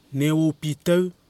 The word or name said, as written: newao